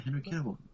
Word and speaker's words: Henry Cavill: Is it pronounced Cavill? – Yeah, Henry Cavill.